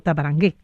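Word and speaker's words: Tavarangue: Claro, las dos están, Tabarangue, con acento en la E.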